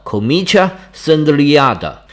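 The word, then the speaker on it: Comitia Centuriata: 这个大会呢，我们一般把它音译作森都里亚大会，或者把它意译成百人队大会，在拉丁语里面读作 Comitia Sendliada。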